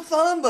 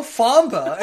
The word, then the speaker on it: Thamba: And I'm like, Thamba, th, th, th, like the. And she goes, oh, I thought you were saying PH.